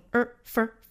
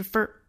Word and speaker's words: for: 最后这个 for 要弱读成 fur. 那英式弱读是 f, 美式弱读是 fur.